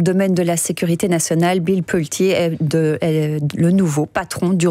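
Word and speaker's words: Pulte: C'est pas Bill Poult, mais Bill Poulty, un riche homme d'affaires, ami de Trump et désormais chef du renseignement américain.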